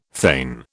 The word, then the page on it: thane: British English